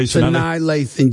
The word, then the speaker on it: Sanaa Lathan: I don't even know how to say this woman. Sanaa Lathan. Okay. – Sanaa Lathan, yes.